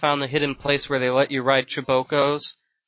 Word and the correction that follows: Chocobo: Okay, alright, I've never heard anyone call it Chibokos because it's spelled C-H-O-C-O-B-O. Chocobo.